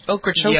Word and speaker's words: Ocracoke: It's, I can pronounce it, is it Ocracoke? Is that how you pronounce that island? – Ocracoke, yeah. I think is right.